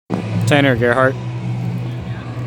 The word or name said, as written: Tanner Gerhart